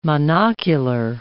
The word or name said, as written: monocular